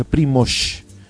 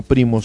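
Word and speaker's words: Primož: Me dijeron que se pronuncia Primoz, no Primoz, como digo yo. Primoz Roglic, que así se pronuncia.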